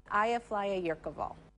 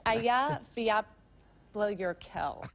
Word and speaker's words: Eyjafjallajökull: Eyja, Flæja, Jökull. It's Eyja, Fjallajökull.